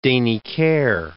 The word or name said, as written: Deniker